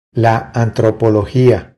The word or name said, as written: La antropologia